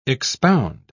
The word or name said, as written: expound